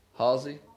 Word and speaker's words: Halsey: There's there's a song out by Housley that says that. Um, and I think I said her name right, Halsey.